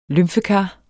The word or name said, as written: lymfekar